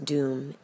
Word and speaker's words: dum: Durga's bija is dum, spelled D-U-M, it is pronounced doom, and a bija is a seed syllable, which means this, like the way that an acorn contains an oak tree.